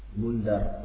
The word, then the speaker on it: Bundar: Qala Ahmad, berkata Al-Imam Ahmad, yakni dalam musnadnya, haddatsana Muhammad ibn Ja'far, Muhammad bin Ja'far julukannya adalah Bundar dengan Goy Nun Dal kemudian Ra, Bundar. Naam.